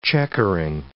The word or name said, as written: checkering